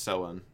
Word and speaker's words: Samhain: It's pronounced Samhain.